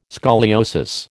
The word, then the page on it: scoliosis: American English